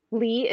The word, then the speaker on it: Leigh: It's Sonia, S-O-N-Y-A. Lee is L-E-I-G-H. Anderson is A-N-D-E-R-S-O-N.